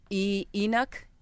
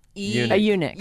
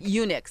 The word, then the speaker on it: eunuch: Because of this episode, they're saying, because of this fact, several viewers turned to the internet to find out exactly what, I don't know how to pronounce this word. Eunuch? – Eunuch. – Eunuch, sorry.